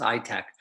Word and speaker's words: ITAAC: Because it's an AP1000 and the only AP1000 in the country, they have something called an ITAAC. It's sort of pronounced ITAC.